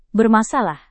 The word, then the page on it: bermasalah: Indonesian